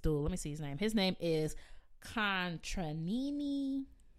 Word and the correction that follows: Contarini: That's not how you say it. That's crazy. You know how many music degrees I have? Anyway, Contranini. Yeah, that's his last name. No. Contarini.